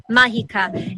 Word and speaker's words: Mágica: Mágica.